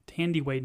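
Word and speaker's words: Thandiwe: And I believe you pronounce her name Thandie Way Newton.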